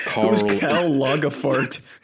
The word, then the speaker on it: Lagerfeld: Karl Lagerfeld. – Yeah, it's weird to pronounce in German, but it's Karl Lagerfeld.